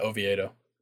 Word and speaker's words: Oviedo: Is his name Oviedo? Is that how you pronounce it? The Pirates pitcher? – Yeah, Oviedo.